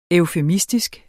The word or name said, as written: EUFEMISTISKE